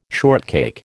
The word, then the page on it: shortcake: American English